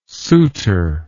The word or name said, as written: Soutter